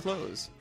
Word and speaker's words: Clowes: Also, Ghost World, the comic, the original one by Daniel Clowe, or Clowe, never know the proper pronunciation. – It's Clowes.